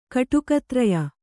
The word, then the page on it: ಕಟುಕತ್ರಯ: Kannada